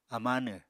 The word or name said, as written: amánw